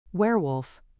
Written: werewolf

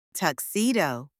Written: tuxedo

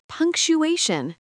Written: punctuation

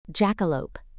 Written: jackalope

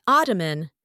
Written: ottoman

